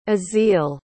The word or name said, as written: aziel